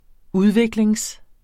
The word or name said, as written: udvikling